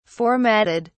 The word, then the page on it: formatted: British English